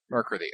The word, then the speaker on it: Mercredi: She'll have to get her to pronounce it, but I think it's Mercredi. – Mercredi, all right. – Because that's the French word for Wednesday, and that's how you say it.